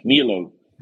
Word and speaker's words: Mierlo: And how do you say the name of the town where you grew up? Mierlo? Mierlo? – It's good enough. Mierlo.